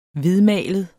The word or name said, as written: hvidmalet